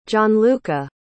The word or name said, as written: gianluca